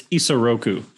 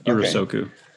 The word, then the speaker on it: Isoroku: Sorry, but I mispronounced. It's Isoroku, not Irosoku.